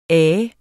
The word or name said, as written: af